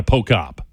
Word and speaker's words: Pochop: If you're finding out about her for the first time, that last name is P-O-C-H-O-P, pronounced Jana Pocop.